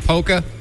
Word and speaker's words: polka: Hey, so do you pronounce the L in polka? – Oh, heck no.